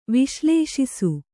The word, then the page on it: ವಿಶ್ಲೇಷಿಸು: viślēṣisu